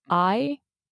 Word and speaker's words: Ikigai: And it's called, I think I'm going to pronounce this incorrectly. I think it's called Ikigai. It's Ikigai. And it's four little circles that you can fill out, essentially.